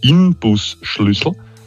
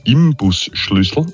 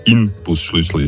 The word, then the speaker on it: Inbusschlüssel: Es heißt Inbusschlüssel. Aber die meisten sagen Imbusschlüssel, aber es ist ja ein Innensechskantschlüssel. Also Inbusschlüssel ist das Wort.